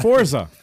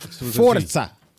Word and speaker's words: Forza: It's Forza. – Forza. – Actually, yeah, what is it? – Forza. – There's no T in it. – But you got to pronounce it like you're Italian.